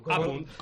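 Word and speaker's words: À Punt: ¿Apunt? ¿Cómo se dice eso? ¿A Fermín? Apunt, ¿no? Yo todavía no me he aclarado. – Apunt, con tilde.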